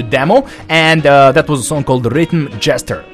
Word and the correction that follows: Gesture: Gesture. I think this is how you pronounce this word.